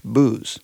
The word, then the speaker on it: bouse: Spelled B-O-U-S-E, but that is pronounced pretty much booze.